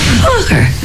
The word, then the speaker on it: Focker: Just like it's spelled. F-O-C-K-E-R. – Fucker.